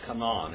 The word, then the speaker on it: Canaan: And actually, you pronounce that Canaan. It's not, we kind of mess it up in English. It's Canaan. Canaan. Can you say Canaan? That's how you say it in Hebrew, Canaan.